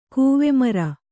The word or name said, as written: ಕೂವೆಮರ